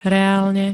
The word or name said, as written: reálne